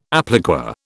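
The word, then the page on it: appliqué: British English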